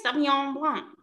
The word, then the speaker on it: Sauvignon Blanc: Is that the correct pronunciation? – Yes, Sauvignon Blanc.